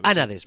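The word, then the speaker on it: Ánades: Pues los Anades, perdón, con acento en la...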